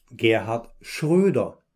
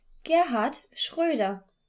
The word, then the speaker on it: Gerhard Schröder: As for the O-umlaut, that sounds... – Ö. – Ö. – The former German Chancellor's surname contains this vowel sound. He was, of course... – Gerhard Schröder. – Gerhard Schröder.